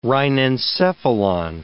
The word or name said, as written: rhinencephalon